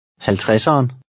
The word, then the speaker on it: halvtredseren: Remember that the V is silent after an L, and the D is again silent. – Halvtredseren.